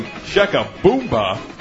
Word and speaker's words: shakaboomba: When you say shakaboomba, the accent goes on the third syllable, boom. Shakaboomba. We say it that way because the power is always in the boom.